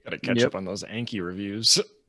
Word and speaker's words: Anki: Yeah, one thing we did, so I, and I think you might be big on Anki, as that is the correct way to say it.